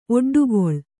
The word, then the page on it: ಒಡ್ಡುಗಲ್ಲು: Kannada